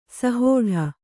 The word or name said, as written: ಸಹೋಢ